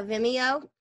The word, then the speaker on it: Vimeo: Yeah, Vimeo.